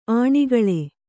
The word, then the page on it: ಅಣುಕೇಳು: aṇukēḷu